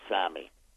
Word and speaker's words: SAMe: It's spelled S-A-M-E, but it's pronounced Sammy.